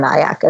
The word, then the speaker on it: Nayak: So it's T-A-N-I-Y-A. And then Nayak is like kayak with an N. So N-A-Y-A-K. So T-A-N-I-Y-A-N-A-Y-A-K.